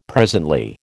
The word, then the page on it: presently: American English